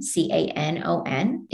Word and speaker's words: Canon: My last name is spelled with one N, C A N O N.